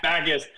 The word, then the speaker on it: Backes: You are a gentleman and a half, so we have been butchering your last name. – Yeah, I'm so sorry. – That's okay. – 40 Backus, minutes, and you have not. – Backus. I've been getting backs my whole life.